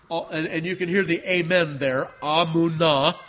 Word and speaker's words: amen: And yet, also, sometimes in the Hebrew Bible, it appears in that original form as the amen. Amen. And that's how the Hebrews say it because of the long vowel there. Amen is how it's said.